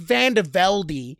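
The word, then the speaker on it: VandeVelde: And the thing about Chris Van Velde is yes, it's pronounced Van Velde. He wanted to make that very clear from the start,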